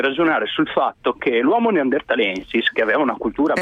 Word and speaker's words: Neanderthalensis: Non si dice Neanderthalis, ma Neanderthalensis. – Bravo, sì, scusami, hai ragione, è verissimo. Neanderthalensis, bravo.